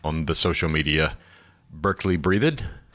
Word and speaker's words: Breathed: Because it looks like you'd say Breathed, but I believe that's the way he pronounces it.